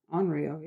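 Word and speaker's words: onryō: It's Onryo, yeah.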